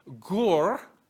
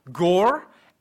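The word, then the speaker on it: gur (גור): The two words that are used here that are translated dwell, one is gur, G-O-O-R, if you want to spell it in English, gore, and the other one is Shahan.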